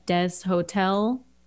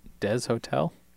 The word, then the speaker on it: Deshotel: It was written by Rob Deshotel. Again, not sure if that's... – Are you saying that right? Deshotel? – It's Rob and mm-hmm. then D-E-S space hotel. H-O-T-E-L. I mean...